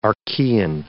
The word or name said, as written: Archean